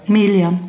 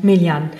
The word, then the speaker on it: Melian: Und du bist nicht nur Autorin, sondern du bist auch Malerin unter der Marke, wie spricht man es aus? – Melian. – Melian.